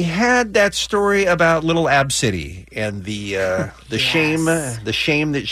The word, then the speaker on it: ABCDE: And people, Ab City, if you weren't listening, her name is ABCDE. That's how it's spelled.